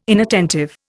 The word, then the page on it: inattentive: Indian